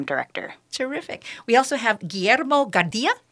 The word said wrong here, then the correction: Guardia: Guillermo Guardia. – Guardia. Mm-hmm like the airport.